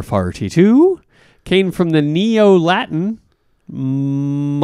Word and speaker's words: molybdenum: It's spelled so crazily that I have a hard time pronouncing it. – Oh. – So I'm in a molybdenum loop. Molybdenum is what it is. – Molybdenum.